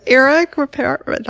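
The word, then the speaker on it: Ripert: And then I love the chef tips from Eric Rippert. I never can pronounce his name correctly. Is it Rippert? – You got it totally right. It's Eric Rippert.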